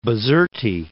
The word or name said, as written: Bizerte